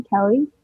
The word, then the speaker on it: Kelly: I'm Kelly. My name is spelled K-E-L-L-Y.